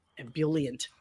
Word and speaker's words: ebullient: Ebullient. – Ebullient? Is that the pronunciation? Ebullient.